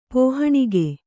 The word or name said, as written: ಪೋಹಣಿಗೆ